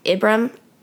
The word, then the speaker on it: Ibram: I found out I said his name wrong. It's Ibram, I think. Ibram X. Kendi. So I think that's how you say his name.